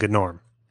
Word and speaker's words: Gnorm: Norm is also spelled with a G. That's the pun. It's a gnome named Gnorm.